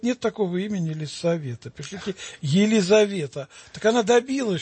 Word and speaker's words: Елисавета: Нельзя писать Елизавета. Надо писать Елисавета. Не З, а С. потому что это имя произносится в церкви не через букву «З», а через «С».